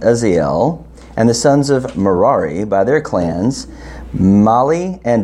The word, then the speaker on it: Merari: Merari. Like Ferrari? All right, we'll go with that.